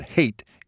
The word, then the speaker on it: Haidt: Thomas Crowe is the psychology graduate student who wrote to us and said, listen, you're pronouncing Jonathan Haidt's name wrong, because we had been saying Jonathan Haidt. Jonathan Haidt, H-A-I-D-T. It is actually Haidt.